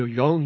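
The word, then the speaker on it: Jong: Radio Young Europa, daily 24 hours, registered on 6170 with 1 kilowatt.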